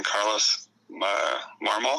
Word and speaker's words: Carlos Marmol: I hated facing Carlos Marmol, is that how you say it? – Yeah.